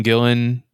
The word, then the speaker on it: Gillen: There's Aidan Gillen, is how you say his last name. Aidan Gillen, Nicholas Holt, John Bernthal, Jack Weber, Tori Kittles.